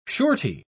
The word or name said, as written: surety